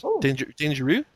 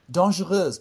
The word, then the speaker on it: Dangereuses: Les Liaisons Dangereuses. Dangereux? – Dangereuses, but yeah, really close.